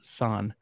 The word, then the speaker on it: san: The Japanese word for 2 is pronounced ni, and the Japanese word for 3 is pronounced san.